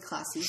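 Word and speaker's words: Klaassen: Yeah, Klaassie, did I say that right? – Yeah, Jordi Klaassie.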